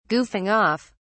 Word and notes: goofing off: British English

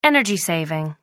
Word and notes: energy-saving: American English

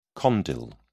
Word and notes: condyle: British English

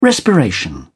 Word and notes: respiration: British English

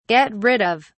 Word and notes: get rid of: British English

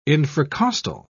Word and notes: infracostal: British English

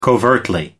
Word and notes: covertly: American English

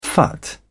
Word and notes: phut: British English

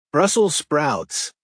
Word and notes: brussels sprouts: American English

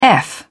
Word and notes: eff: British English

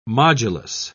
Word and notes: modulus: British English

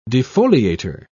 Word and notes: defoliator: British English

